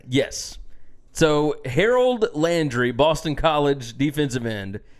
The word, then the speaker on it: Harold: Vince Young called him Honor Landry. – Honor Landry. – There's no If you just enunciate it. If you just, like, phonetically sound it out, you'll say it right. – Like, even if it was Harold. Yeah. Like, Harold. That'd be fine. – Yeah. – But Honor is not fine.